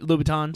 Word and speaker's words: Louboutin: Louboutins.